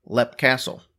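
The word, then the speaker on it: Leap Castle: And talk about one of the most, I will say, talked about haunted locations in the entire world, Lepp Castle. – Like L-E-P? – Well, no. Like, very much like. It's spelled L-E-A-P. – Oh, okay. – But they don't pronounce it leap.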